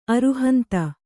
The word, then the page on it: ಅರುಹಂತ: aruhanta